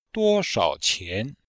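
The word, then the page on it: duoshao qian: duo1shao3qian2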